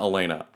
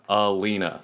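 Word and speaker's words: Eleana: Her name is Alina J. Kim, not Elena, Alina.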